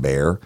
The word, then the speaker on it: Bexar: And she said, the, uh, "Yeah, it's it's actually pronounced bear."